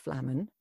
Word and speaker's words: Flamman: So my name is Katie Flamman,